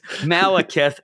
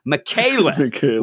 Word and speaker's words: Malekith: we, we know it's Malekith and not Michaelith.